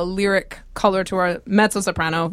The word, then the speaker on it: coloratura: Coloratura soprano. – Oh, man, I got to write this down. – Mm-hmm. – Cholera. – Yeah, coloratura. – Coloratura. – That's right.